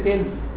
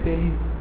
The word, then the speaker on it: Thales: So you don't say Thales, you say Thales.